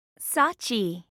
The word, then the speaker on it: Sachi: P-E-T-E-R. – 3. – What's your name? – Sachi. – How do you spell that? – S-A-C-H-I.